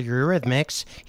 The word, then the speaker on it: Eurythmics: I added a couple extra syllables in there. That's the Eurythmics.